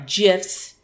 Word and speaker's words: GIFs: Oh, I'm sorry, GIFs. they made sure to say, and I always have to remind myself that the G is pronounced like a J.